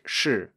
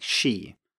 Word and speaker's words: shi: But for example, if we have a word like shi in Mandarin, so that's spelled S H I uh, with a fourth tone, many native speakers of English, when they start learning Chinese, pronounce this something like she.